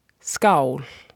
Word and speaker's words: skál: And a very important one is if you're going to cheers to each other in Icelandic, that is skál.